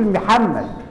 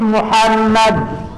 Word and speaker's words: محمد: احنا بنغلط في نطق اسم النبي، معظمنا بيغلط بيقول محمد، نطقها الصحيح محمد، وردت في القرآن 4 مرات محمد، لكن احنا بنقول عمك محمد. لا يا حبيبي، قول محمد.